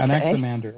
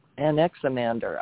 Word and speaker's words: Anaximander: He was Anaximander. Is that how you say it? – Good enough. – Yeah? Okay. – Anaximander. That's the way I say it, but anyway. – Anaximander.